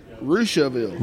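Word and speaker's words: Russiaville: How do you say it? Prussiaville? – Rushaville.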